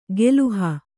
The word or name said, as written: ಗೆಲುಹ